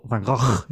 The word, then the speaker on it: Van Gogh: So if you could create art like Van Gogh or Van Gogh, if you want to say it correctly, or for you know you could create a Banksy or whatever, right?